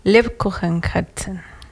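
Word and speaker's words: Lebkuchenherzen: Lebkuchen Herzen. ¿Lo pronuncié bien? – Sí, Ah.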